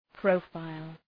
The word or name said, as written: profile